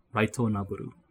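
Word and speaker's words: Raito Noboru (ライトノベル): So light novel will be pronounced more like Raito Noboru.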